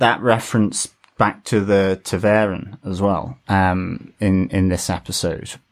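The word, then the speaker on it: ta'veren: Taveren, E-H-N. So that's how they pronounce it, Taveren.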